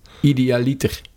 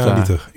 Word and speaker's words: idealiter: Dit woord spreek jij waarschijnlijk verkeerd uit. Het is niet idealiter, maar wel idealiter. De klemtoon op de A.